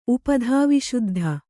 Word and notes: ಉಪಧಾವಿಶುದ್ಧ: upadhāviśuddha

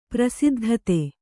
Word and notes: ಪ್ರಸಿದ್ಧತೆ: kannada

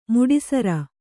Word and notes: ಮುಡಿಸರ: muḍi sara

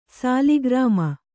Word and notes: ಶಾಲಗ್ರಾಮ: śalagRāma

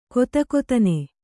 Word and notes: ಗದಗದನೆ: kannada

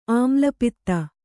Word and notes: ಆಮ್ಲಪಿತ್ತ: āmlapitta

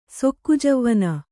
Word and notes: ಸೊಕ್ಕುಜವ್ವನೆ: sokku javvane

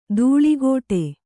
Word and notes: ದೂಳಿಗೋಟೆ: dūḷigōṭe

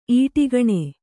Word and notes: ಈಡುಗಾಣು: īḍugāṇu

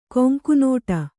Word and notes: ಕೊಂಕುನೋಟ: Kannada; koŋku nōṭa

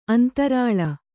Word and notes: ಅಂತರಾಳ: antarāḷa

